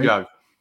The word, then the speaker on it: Djo: It's DJO. So Joe, maybe. – Joe. – But again, or Joe. Yeah, maybe you're right. Joe.